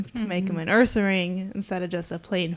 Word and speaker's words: Ursaring: Is that how you say it? – Ursaring. – Yeah, Ursaring.